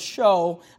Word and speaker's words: shew: I want you to make note of that word show. I know it's spelled S-H-E-W, but it's our word S-H-O-W.